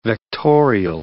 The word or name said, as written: vectorial